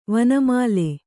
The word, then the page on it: ವನಮಾಲೆ: vana māle